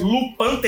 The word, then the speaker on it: Lupin: Lupão III, né? Se escreve Lupin.